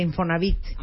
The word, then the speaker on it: Infonavit: Subdirector general de Infonavit. Ya ah. quedamos que es con acento en la I, ¿verdad?